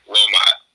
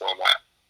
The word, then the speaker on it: Wilmot: You did pronounce my last name right. Wilmot. Some people pronounce it with like an N and stuff, but no, it's Wilmot.